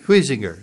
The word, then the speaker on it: Huizinga: Huizinga, is that? – Huizinga, yeah. H-U-I-Z-E-N-G-A.